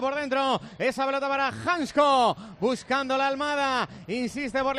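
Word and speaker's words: Hancko: Si a mí me dice Janko una vez, yo ya... – Que no, ya está. – Tú dilo como haya que decirlo, es que yo no sabía que se pronunciaba Hansko.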